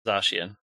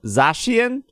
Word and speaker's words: Zacian: There's no A at the end of the Zacian. – Oh, Zacian? Sorry. Zacian and Zamazenta.